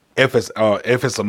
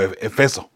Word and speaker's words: Éfeso: Bueno, creo que la ciudad se llama Éfeso, pero algunas personas lo llaman nomás Éfeso nada más, o Efeso, sin el acento en la E, ¿no?